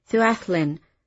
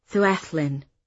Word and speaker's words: Llewellyn: His name needed to be Llewellyn, pronounced the proper way. Llewellyn, the way they do in Wales. Yes, Llewellyn. Llewellyn, I would say, if ever you have a case of piscine poisoning to solve, I should be happy to help.